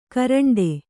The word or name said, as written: ಕೋರಂಟಿ